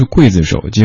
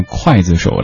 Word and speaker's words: 刽子手: 一首歌它红的程度可以从很多个角度得到印证，比如说这首歌，它红的让您对一个字的读音有了一些习惯上的改变，明明是刽子手，结果刘天王唱了之后，大家都念筷子手了。